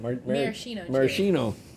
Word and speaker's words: maraschino: Marcino, or how do you say that? – Maraschino.